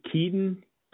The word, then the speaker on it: Keaton: And my name's Keaton, by the way. – Okay. What did you say your name is? – My name's Keaton, K-E-A-T-O-N.